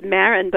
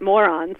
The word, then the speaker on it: Maron: Well, before I get to the riled up, his name is pronounced Maron, but I think most Israelis these days would pronounce it Moron, so it's very appropriate that you said Maron.